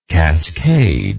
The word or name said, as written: cascader